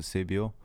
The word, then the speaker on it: Cebio: Äh, ja, gut, in, in Afrika sagt man es ein bisschen anders. Da ist die Betonung ein bisschen auf dem O, also Sebio.